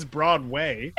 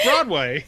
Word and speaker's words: Broadway: it's a wonder you're not on Broadway. I mean, they're already making fun of that element already. So it's... I love how Ron Silver says Broadway, because he says it like it's two words.